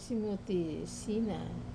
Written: simuti sina

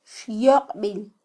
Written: xyoqbil

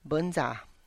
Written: bInza

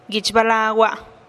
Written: guich balagua

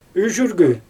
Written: yʒyrgy

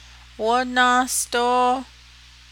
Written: wónáásdóó

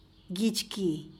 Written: gitx kii

